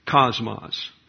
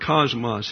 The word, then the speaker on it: cosmos: But if you want to call it cosmos, that would be the English pronunciation. The dictionary allows either. And if you're going to go with the Greek, it's cosmos.